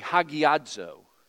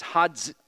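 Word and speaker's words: hagiadzo (ἁγιάζω): And you pronounce it this way, hagiadzo. In the English transliteration, there's not a D there, but when you're pronouncing the Greek word, it's hagiadzo.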